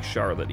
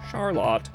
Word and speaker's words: Charlotte: It's spelled like Charlotte, even though evidently pronounced Charlotte.